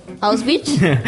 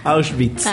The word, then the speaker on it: Auschwitz: Esto es porque en esta fecha, en 1945, el ejército rojo soviético liberó el, cambio, el, campo de concentración y exterminio de Auschwitz. – Auschwitz.